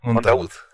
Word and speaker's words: Montaud: ¿el apellido está bien pronunciado, Po? Es, como, como se lee, Mont Montaud.